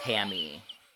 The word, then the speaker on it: Pame: It's P-A-M-E. – To Pame? – It says it's pronounced Pammy.